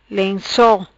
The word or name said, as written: Lenco